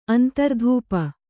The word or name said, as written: ಅಂತರ್ಧೂಪ